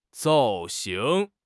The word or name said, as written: 造型